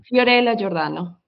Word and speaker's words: Fiorella Giordano: So how do you pronounce your name properly? – Fiorella Giordano.